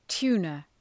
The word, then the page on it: tuna: ‘tu:nə